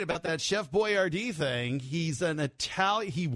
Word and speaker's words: Boiardi: And then here's how you spell his last name. You'll get this here when I spell it. B-O-I-A-R-D-I. Boyardee. – Boyardee. – And so they anglicized it, they said. They made it, you know, Boyardee. – Boyardee, so we could read it.